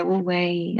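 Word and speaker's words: wu wei (無為): One of the things that I noticed that you speak of is this principle of wu wei. Am I saying that right? – Yeah, wu wei.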